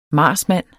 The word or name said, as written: marsmand